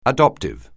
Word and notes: adoptive: anglais

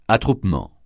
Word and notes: attroupement: francais

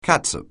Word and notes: catsup: anglais

